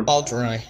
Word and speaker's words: Baldroy: I think how do you pronounce the chef's name broadly? – Baldroy or Baldroy. Okay.